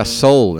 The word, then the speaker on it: Seoul: Es que en inglés Seoul se, pronuncia soul, entonces sería I'm so cool in soul.